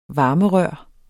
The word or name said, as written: varmerør